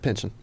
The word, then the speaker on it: Pynchon: How do you say it? – Pynchon. – Pynchon. – Yeah. – I hear that that's incorrect, but it's so satisfying to say Pynchon. – I think that's right.